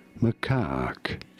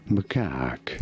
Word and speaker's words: macaque: Macaque. – Macaque! – You do want to stress on the second syllable, on the cack syllable. Macaque. Pretty straightforward once you know. Macaque.